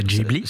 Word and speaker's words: Ghibli: Comment il l'a prononcé? – Ghibli. – Ça se dit comme ça d'ailleurs, donc voilà.